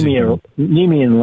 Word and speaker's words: Nemea: He said he's been working on the line of I think it's Nemea. Is that how you pronounce that? For a few years now. He said he's Nemean lion.